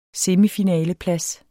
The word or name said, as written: semifinaleplads